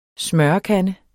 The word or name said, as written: smørekande